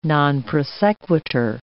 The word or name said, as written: non prosequitur